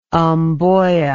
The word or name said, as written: mboya